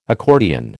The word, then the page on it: accordion: American English